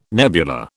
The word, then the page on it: nebula: British English